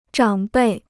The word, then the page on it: 长辈: zhǎng bèi